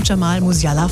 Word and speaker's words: Jamal Musiala: Joshua Kimmich vom FC Bayern und auch Jamal Musiala vom FC Bayern, richtig? – Hey, sogar richtig ausgesprochen.